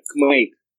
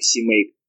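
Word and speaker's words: CMake: Кстати, кстати, они его произносят как кмейк, не как симейк.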